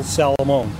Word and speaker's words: Salamone: Michael Salamone.